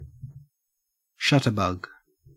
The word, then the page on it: shutterbug: Australian English